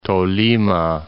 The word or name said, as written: Tolima